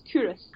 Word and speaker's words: Culross: But I think probably Cooris and Blackness are probably my favourite places. – I would love to go to, if we could make it to Cooris. – It's spelled C-U-L-R-O-S-S, Cooris.